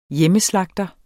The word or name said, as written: hjemmeslagter